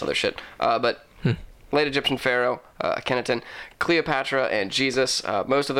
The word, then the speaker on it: Akhenaten: The list includes the late Egyptian pharaoh, Akhenaten. – How you spell that? – A-K-H-E-N-A-T-E-N.